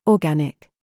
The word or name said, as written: KIT